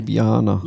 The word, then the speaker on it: Ljubljana: Actually, it's funny you say that because we did get a message today from somebody from Ljubljana. Ljubljana? Ljubljana. Ljubljana? How do we say it? – Ljubljana. – Ljubljana. Always forget.